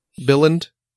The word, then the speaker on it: Billund: Billund? – Yeah, where Lego's is located.